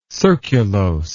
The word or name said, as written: surculose